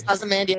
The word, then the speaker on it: Ozymandias: Yes, Ozymandias.